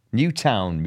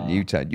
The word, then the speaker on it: Newtown: Rob here from Newton, Mid Wales. Newtown, Mid Wales, that's it, Newtown.